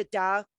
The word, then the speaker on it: Tao: It's technically pronounced almost like with a D, the Tao of Dawson.